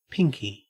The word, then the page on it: pinky: Australian English